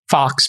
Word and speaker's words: Faux: Zeke Fox, pronounced like the animal, but spelled F-A-U-X.